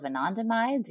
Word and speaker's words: anandamide: And it's being investigated for pain relief effects, for, you know, strengthening the role of anandamide, you know, one of our endogenous cannabinoids, which I think is really cool.